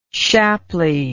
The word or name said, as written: shapley